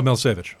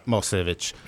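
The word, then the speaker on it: Milošević: Oh, Milosevic. – Milosevic.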